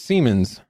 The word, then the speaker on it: Siemens: But yeah, so is it Siemens or Simons? Can like if you're gonna comment, at least let us know. Are we pronouncing it right or are we pronouncing it wrong? – The consensus here is Siemens. – Siemens. All right, we're just gonna roll with Siemens.